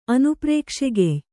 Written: ಅನುಪ್ರೇಕ್ಷೆಗೆಯ್

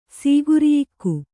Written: ಸೀಗುರಿಯಿಕ್ಕು

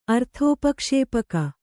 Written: ಅರ್ಥೋಪಕ್ಷೇಪಕ

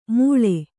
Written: ಮೂಳೆ

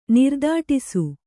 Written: ನಿರ್ದಾಟಿಸು

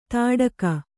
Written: ತಾಡಕ

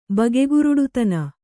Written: ಬಗೆಗುರುಡುತನ

